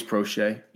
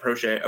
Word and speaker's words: Proche: I know everybody's talking about Rashad Bateman and James Proche. Is that how you say it? It's James Proche. – Yeah, Proche.